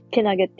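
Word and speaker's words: 健気: これってけなげですねって読むもしかして。けなげですねでいいんかなけなげって読む。ありがとう。そうやんな。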